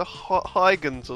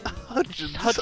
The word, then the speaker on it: Hudgens: I thought it was like Vanessa Huygens or something. Or I thought the D what? Was silent.